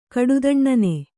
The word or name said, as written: ಕೊಟ್ಟೆದೊನ್ನೆ